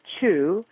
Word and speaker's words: Chiu: And his name is Chu. That's spelled C-H-I-U. And so we call it Chu ware.